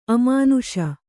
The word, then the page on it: ಅಮಾನುಷಿ: amānuṣi